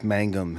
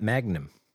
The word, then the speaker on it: Mangum: How do you pronounce his name? – Jeff Mangum? – It is. That's correct. – Yeah. – It's not Magnum. – It's not Magnum. – I used to think it was Magnum.